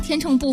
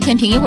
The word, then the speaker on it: 天秤: 吕哥说，论闷骚，大天秤不服啊。一会儿读天平，一会儿读天秤，满足一下这个不同读音朋友的需求哈。